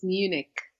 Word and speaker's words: Munich: No, it's Munich. – Munich. – Yeah, in English, it's Munich. In German, it's München.